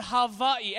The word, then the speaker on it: Hawaii: It's actually called Hawaii. Everybody say Hawaii with me. Anytime you see a W in the Hawaiian language, it's a V pronunciation, okay? So we say Hawaii.